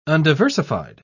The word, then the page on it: undiversified: British English